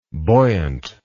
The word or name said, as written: buoyantly